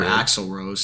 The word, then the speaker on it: Axl Rose: Or Alex Rose. – Yeah. I don't know. – Or Axl Rose, sorry.